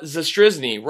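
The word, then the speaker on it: Zastryzny: We now welcome on a very special guest, Chicago Cubs pitcher and 2016 World Champion, Rob Zastrzyzny. Rob, did I say that right? – You said it perfect.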